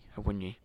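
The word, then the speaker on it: Awoniyi: Is it Awani? – Awunyi. Yeah, it might be Tyler, but last name Awunyi.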